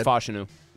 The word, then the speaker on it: Fashanu: They take Fashanu from Penn State. I think I said his name right there. – Yeah, it is Fashanu.